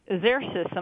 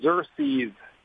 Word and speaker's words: Xerces: So tell me first, Xerces, am I pronouncing that correctly? – It's close. It's actually the Xerces Society.